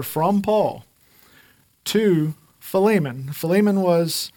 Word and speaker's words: Philemon: As long as you put the stress and emphasis on the li, it's okay. You're saying it right. It's Philemon or Philemon. But not Philemon. So if you've said it like that, you're saying it wrong.